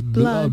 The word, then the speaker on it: blood: Claro, y por ejemplo, la, doble O, la doble O que se pronuncia como U, cuando es sangre, pues, no es una U, es, es blood.